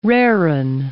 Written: raring

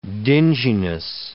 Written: dinginess

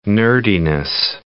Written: nerdiness

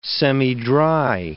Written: semidry

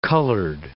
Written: colored

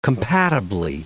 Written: compatibly

